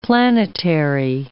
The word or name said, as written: planetary